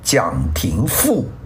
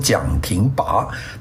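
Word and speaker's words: 蒋廷黻: 那个他呢就跟了一个有名的历史学家，这个人呢叫蒋廷富，这个字呢我原来讲错了，我把它读成蒋廷拔，他其实这个字读富啊，这字很难写啊。大家这个蒋廷富后来做了国民政府的一个官员啊，当然是蒋廷富去做官员的时候呢，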